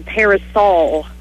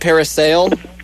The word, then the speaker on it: parasol: So the proper pronunciation is what again? – Parasol. – Parasol. – P-A-R-A-S-O-L, I think. – I'm going to write it Saul, S-A-U-L.